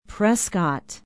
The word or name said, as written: prescott